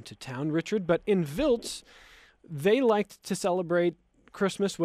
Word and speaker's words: Wiltz: It's spelled W-I-L-T-Z. It's pronounced Wiltz. W is a V.